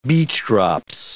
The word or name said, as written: beechdrops